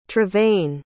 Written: trevain

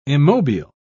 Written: immobile

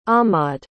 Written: ahmad

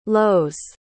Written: lowes

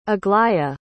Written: aglaia

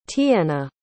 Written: tiyenna

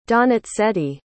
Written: donizetti

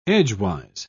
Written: edgewise

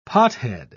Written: pothead

